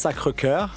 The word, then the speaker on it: Sacré-Cœur: Yeah, so that's Sacré-Cœur, yes, for the correct real pronunciation.